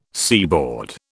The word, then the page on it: seaboard: British English